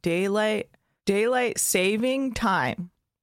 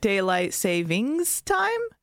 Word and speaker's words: daylight saving time: Daylight saving time. That's how you say it? – Yeah. – Not daylight savings time? – It turns out.